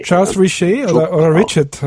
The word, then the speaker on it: Charles Richet: wie es zum Beispiel wie hieß er nochmal, Charles Richard war. – Charles Richet, habe ich es jetzt ausgesprochen.